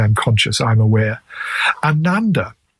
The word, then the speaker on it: ananda: Now, the long A, ananda, means great.